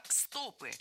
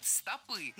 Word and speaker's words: стопы: Мы услышали интересную информацию про стопы. И ударение в этом слове было поставлено верно. Хотя раньше литературным был вариант стопы. Но время идет вперед, ударения меняются.